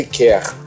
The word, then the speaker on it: CAIR: É o Conselho sobre Relações Islâmico-Americanas, se escreve Cair, C-A-I-R, mas se lê quer,